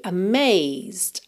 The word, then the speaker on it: amazed: I'm sure you'll be amazed, amazed. Amazed, você basicamente vai ouvir mesmo até o Z, tá? Porque a letra E não é pronunciada.